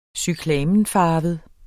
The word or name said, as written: cyklamenfarvet